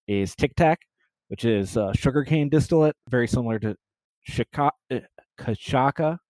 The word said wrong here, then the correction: cachaça: which is from Brazil. – Cachaça. – I'm struggling with my pronunciations today.